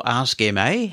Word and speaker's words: Ask MA: Is it Ask Ma or Ask MA? How do you pronounce it? – We have internally called it Ask Ma. It's a short acronym for Ask Microsoft Accessibility.